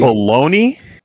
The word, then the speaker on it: bologna: Lunch meat that rhymes with Tony. – Three. – Bologna? – There you go.